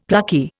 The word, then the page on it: plucky: Indian